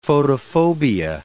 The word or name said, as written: photophobia